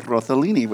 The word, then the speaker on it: Rossellini: Rossellini, which is how it's pronounced.